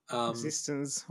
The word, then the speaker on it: eXistenZ: I don't know how it's pronounced. The one about VR. – Existenz. Oh.